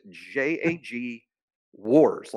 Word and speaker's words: Jaguars: I try to pronounce it J-A-G wars, like W-A-R-S, like multiple wars, the world wars.